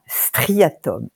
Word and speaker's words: striatum: Striatum, S-T-R-I-A-T-U-M.